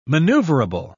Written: maneuverable

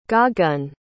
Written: Gagan

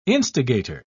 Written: instigator

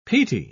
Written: peaty

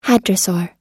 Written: hadrosaur

